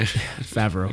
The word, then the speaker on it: Favreau: Favreau.